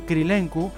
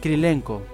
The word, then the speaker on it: Krilenko: Como curiosidad, la pronunciación Krilenku es rusa o moldava, cuando debía ser búlgara la nacionalidad del personaje. En ese caso tendría que haberse llamado Krilenko.